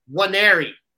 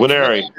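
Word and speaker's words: Nwaneri: This happens every recruiting cycle, and I actually asked Steve Wolfgang about this guy's name, Williams, and it's spelled N W A N E R I. I feel like I'm on the ESPN spelling bee as I close my eyes, and it's I believe it's pronounced Wanneri.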